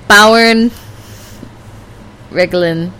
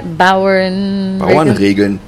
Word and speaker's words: Bauernregeln: Bauern Regeln. Farmer lore jokes. How do you say that? Bauernregeln.